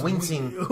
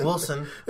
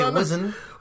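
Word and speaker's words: Wisin: It's Wincing. – It's not Wilson. – Wilson. – It's Wilson not and Yandel. It's W-I-S-I-N. It's not Wilson.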